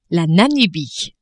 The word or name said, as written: Namibie